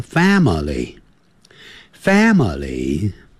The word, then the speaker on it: family: I have 就是我有 a small，你看那个 S-M-A-L-L 结尾有两个 L，所以舌尖要抵上去， small Small I have a small family. Family 是三个音节， Family 不要念成 family，不是这样念的， Family Family。